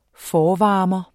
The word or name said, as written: forvarmer